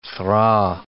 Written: thraw

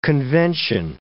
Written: convention

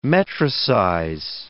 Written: metricize

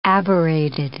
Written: aberrated

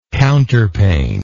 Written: counterpane

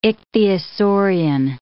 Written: ichthyosaurian

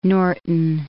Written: Norton

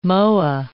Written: moa

